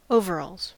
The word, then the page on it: overalls: American English